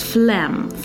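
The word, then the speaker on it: phlegm: Нужно сказать, что у многих есть cough, это кашель, но при коронавирусе не должно быть у вас флем. Флем – это мокрота. Такое слово странно читается, конечно. Пожалуйста, обратите внимание на то, как оно пишется и на то, как оно читается. Флем.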